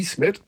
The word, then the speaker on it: Ismet: Das, das I, das große I mit Punkt drauf, also bei Ismet, mhm. wird ausgesprochen wie das deutsche I in Miete. Miete. – Also lang I. – Wie langes I. Langes I, Ismet.